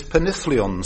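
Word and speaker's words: penillion: What, please, is Penithlion singing? Not so much what as whence comes Penithlion singing? – Well, I should think from your exquisite pronunciation of presumably a double L.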